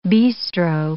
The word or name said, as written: bistro